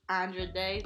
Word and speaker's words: Andra Day: Well, I, I said "Rise Up" by Andra Day. That's how you say her name.